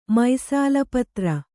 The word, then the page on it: ಮೈಸಾಲಪತ್ರ: mai sāla patra; Kannada